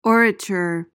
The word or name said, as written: orature